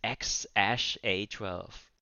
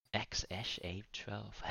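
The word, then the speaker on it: X Æ A-12: How Elon Musk pronounces XAA12. Ähm. – Leitung, diese Bedeutung von dem Wort. – Ach, das heißt X A12. X A12.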